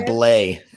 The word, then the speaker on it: Blais: How do you say his name? – Sammy Blais. The S is silent. Sammy Blais.